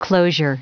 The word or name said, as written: closure